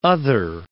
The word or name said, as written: other